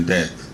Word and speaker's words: debt: Funny thing about money also, I realized that almost all countries in the world are now in debt. Stupid silent letters. Debt. Why there's a B?